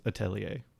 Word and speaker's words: atelier: It is a French word, and it is pronounced atelier.